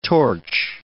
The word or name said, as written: torch